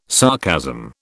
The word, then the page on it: sarcasm: British English